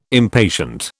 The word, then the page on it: impatient: British English